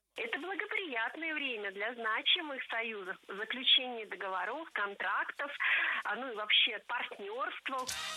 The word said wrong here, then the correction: договоров: Не договоров, а договоров. Вспоминаем тему выпуска. Существительные с неподвижным ударением на основе. И это значит, что ударение в слове «договор» не двигается, всегда остается на третьем слоге.